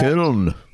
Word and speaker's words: Köln: I think, how do you pronounce Cologne, by the way? Is it Köln or something like that? – Köln. – Cool, that's it. Exactly.